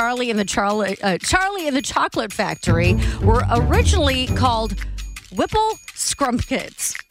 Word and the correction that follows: Scrumpets: Whipple Scrumpets.